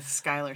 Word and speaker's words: Schuyler: Schuyler sisters.